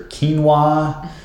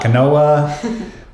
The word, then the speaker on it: quinoa: So that would be your brown rice, your quinoa. Finally said it right, it's not quinoa.